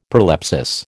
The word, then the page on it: prolepsis: American English